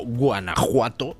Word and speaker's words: Guanajuato: La pronunciación es J. Y de hecho, pues en México tienen ustedes pueblos como Guanajuato, que se pronuncia como México con J, y que se escribe con J, cuando durante todo el siglo XIX permaneció la coexistencia de las dos grafías, Guanajuato con J y Guanajuato con X pronunciado J.